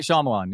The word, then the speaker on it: Shyamalan: How do you say that director's last name? Shyamalan? – Shyamalan? – M. Night. – Sounds right. Yeah, M. Night Shyamalan, yeah.